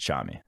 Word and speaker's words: Nchami: I believe that one's just Chami, who we haven't talked about much in this pod. There's a silent N in Chami? – Silent N.